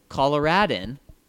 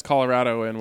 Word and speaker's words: Coloradan: As a native Coloradan, Did I say that right? – He says it's Coloradoan, which is wrong.